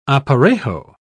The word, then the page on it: aparejo: British English